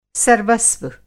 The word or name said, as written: सलीबी